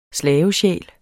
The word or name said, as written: slavesjæl